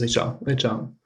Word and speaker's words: Ritschard: I think in French they say Richard. – It comes down to two syllables in French almost.